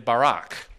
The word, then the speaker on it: Barak: There's Deborah and Barak, not Barack Obama, okay? His name is Barak, like Ehud Barak.